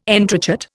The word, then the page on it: entrechat: Indian